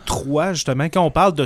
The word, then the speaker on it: Troyes: Et puis Troie s'appelle T-R-O-I-E. E. – Oui. – Tandis que là, Chevalier de Troie, c'est T-R-O-Y-E-S. Alors, on pourrait dire de Troie, mais c'est. – on le, prononce quand même Troie.